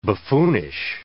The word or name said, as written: buffoonish